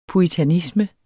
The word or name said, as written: puritanisme